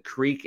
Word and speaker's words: Kriek: And Creek, in this case, is spelled K-R-I-E-K.